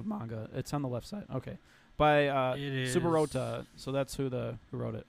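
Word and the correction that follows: Saburouta: I cannot pronounce that, but Saburota. There we go.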